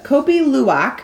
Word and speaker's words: Kopi Luwak: It's called the Kopi Luwak coffee. K-O-P-I, second word, L-U-W-A-K. – Okay. – The Kopi Luwak.